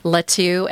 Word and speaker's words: Latu: So my name is spelled Jean, J-E-A-N, Latu, L-A-T-U.